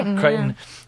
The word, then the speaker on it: Crichton: Definitely Crichton.